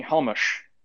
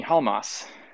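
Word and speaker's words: Halmos: And Helmholtz Halmus, in this automathography, by the way, says that, no, don't call me Halmush. Call me Halmas. – Interesting. – So yeah, he sort of like sheds the Hungarian pronunciation of his last name, which is kind of interesting.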